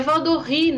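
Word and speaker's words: Valldoreix: É Valdorré, né? Porque é Catalunha.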